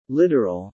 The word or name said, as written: literal